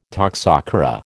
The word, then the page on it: toxocara: American English